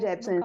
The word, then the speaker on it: Jepsen: Jepsen? Jameson? Eu não sei pronunciar o nome dela. – Carly Rae é Jepsen.